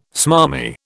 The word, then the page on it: smarmy: British English